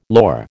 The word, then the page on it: lor: American English